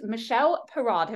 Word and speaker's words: Michele Parad: This is Michele Parad.